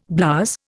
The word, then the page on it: blouse: Indian